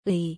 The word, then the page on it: 의: korean